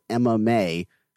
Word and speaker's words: Emma May: He directed a movie called Emma May, which, as I was recording that episode, anytime I would say it, I kept it occurred to me that it sounded like the fighting sport, MMA. So pronouncing it, I remember just like, Emma May.